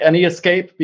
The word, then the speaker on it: NEScape: I always called it NESCAPE because NES... S starts escape, so it's kind of a wordplay. I always enjoyed that, but people started pronouncing it as an escape.